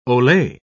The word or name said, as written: Ole